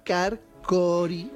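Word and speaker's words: Takarkori: Las momias de Takarkogri. Como lo he dicho mal, ¿no? – Bueno, si te contentas con eso, tú misma. – Takarkogri. – Ahí, muy bien.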